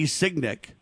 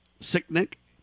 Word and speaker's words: Sicknick: Signick, is that how you say it? Sicknick? – Yeah, I think that's correct.